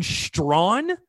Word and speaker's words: Strachan: But Jordan Strawn, that's how they said it.